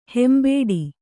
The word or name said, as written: ಹಿಂಬಡ